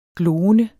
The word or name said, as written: gloende